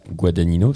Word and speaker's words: Guadagnino: En 10e position, on en parlait tout à l'heure, Challengers de Luca Guadagnino, c'est bon, je l'ai bien prononcé. – Yes, bien joué.